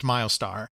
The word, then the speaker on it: Mylstar: That sounds very much like the kind of thing that's marketing by committee, and it's Miles spelled M-Y-L-S-T-A-R, but it's pronounced Milestar.